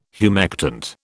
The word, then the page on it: humectant: British English